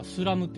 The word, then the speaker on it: SLAM: これは、あの、 SLAM って書いて、やっぱスラムって読むんですスラムって読んでますね。 – なるほど。 – スラムダンクのスラムスラムダンク、確かに。スラムダンク。はい。